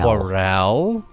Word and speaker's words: Chorale: Chorale. – I can start the whole segment over if you guys want. – No, that's okay. Just keep going. – Chorale would be the correct way to say that.